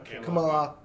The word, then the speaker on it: Kamala: Kamala? Did I say it wrong? – I said it right either. But Kamala. Yeah.